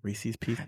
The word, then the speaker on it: Reese's Pieces: It rhymes. Reese's Pieces. It's not Reese's Pieces. Pieces isn't a fucking word.